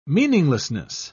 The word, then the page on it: meaninglessness: British English